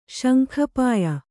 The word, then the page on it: ಶಂಖಪಾಯ: kannada